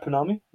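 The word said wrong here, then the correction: pounamu: Is that, am I saying that correctly? – Pinami. – Punamu. Make sure everyone carries their Punamu for George.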